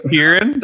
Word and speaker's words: Tieran: My name's Tieran. That's how you pronounce it.